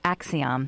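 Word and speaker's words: Acxiom: Sorry, I'm mispronouncing the word. It's Axiom. Thank you.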